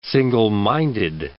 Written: single-minded

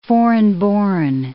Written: foreign-born

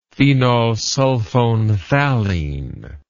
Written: phenolsulfonephthalein

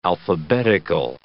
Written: alphabetical

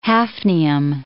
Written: hafnium

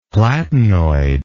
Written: platinoid

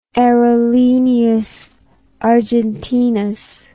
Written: Aerolineas Argentinas

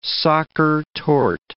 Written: Sacher torte

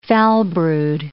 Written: foulbrood

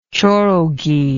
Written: chorogi

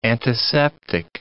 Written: antiseptic

